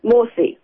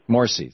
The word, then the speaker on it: Morsi: And it seems to me that because Morsi, am I pronouncing that right? – Morsi. – Morsi, thank you.